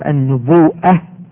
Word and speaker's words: النبوءة: النبوة، كلمة نبوة تنطق على وجهين، يقال النبوءة والنبوة.